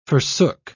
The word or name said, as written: forsook